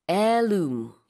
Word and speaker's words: heirloom: 네, 그래서 H 발음하지 않습니다. heirloom.